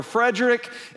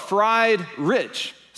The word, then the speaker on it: Friedrich: This name, I didn't even know how to say it, whether it was Friedrich or Frederick, and there's still some uncertainty there.